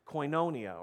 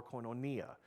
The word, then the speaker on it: koinonia: However it's translated, the Greek word is koinonia, or you've probably heard it pronounced before, koinonia.